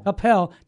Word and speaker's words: Coppell: Coppell,